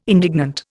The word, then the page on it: indignant: Indian